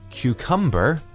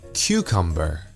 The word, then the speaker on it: cucumber: Because of Cantonese, many people in Hong Kong tend to place the emphasis on the second syllable for words that have three syllables. For example, they would say cucumber and hamburger.